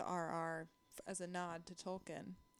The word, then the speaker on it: Tolkien: Is it pronounced Tolkien? – Tolkien. I say Tolkien. – I say Tolkien. – Oh, mate, I think it is Tolkien. J.R. Tolkien, yeah, not Tolkien.